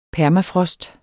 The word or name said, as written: permafrost